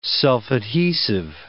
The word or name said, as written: self-adhesive